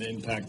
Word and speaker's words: impact: I didn't say it didn't impact the game. – Oh, boy. – Oh, boy. No, I did not say that. – You have the accent on the wrong syllable.